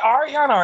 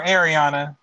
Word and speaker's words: Ariana: First of all, is it Ariana or Ariana? – I don't know. – Or is it both? – It's both.